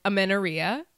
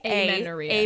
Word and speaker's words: amenorrhea: Amenorrhea, which, am I pronouncing it right? Because in my head, I've been saying, like, it's amen and diarrhea.